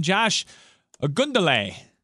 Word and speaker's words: Josh Ogundele: he is 6'10", Josh Ogundele. I believe that's how you pronounce it.